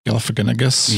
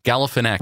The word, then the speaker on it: Galifianakis: Galifianakis. Right. Say it. Yeah, say it with me.